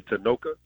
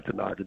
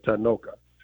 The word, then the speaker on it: Tanoka: Well, if you know, first and foremost, my name is actually Tanoka, but because of our lazy tongue here in America, the name, we know, I go by Tanaka just because everybody knows me as that. But the proper pronunciation is Tanoka, and it is a term, it's a name that comes from the Swahili dialect meaning brave warrior.